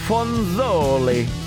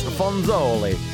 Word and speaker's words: Fronzoli: This new one is called Fronzoli, and you have to say it like that. It's like prosciutto or burrata. It's Fronzoli.